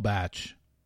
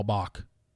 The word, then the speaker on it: Bach: And that's why I promote people like Dave Neal and Game of Roses and She's All Batch or She All Bach. That is hilarious that Ben thought that's how you pronounced it. I get it. B-A-C-H.